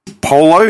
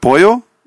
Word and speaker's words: pollo: When people say polo instead of pollo, you know, Spanish chicken.